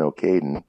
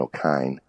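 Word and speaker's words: Ó Cadhain: By a guy named Martin O'Caden, or it's actually pronounced Martin O'Kine. Uh-huh. Looks like Caden, but he was an Irish writer, and he wrote in Irish.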